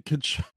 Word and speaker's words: Tkachuk: Saying that he kept pronouncing the T. The silent T in Tkachuk.